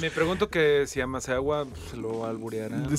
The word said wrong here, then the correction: Maesawa: Maesawa. Lo pronuncié mal, perdón.